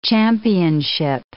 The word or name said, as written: championship